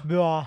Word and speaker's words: Beau: โบโบ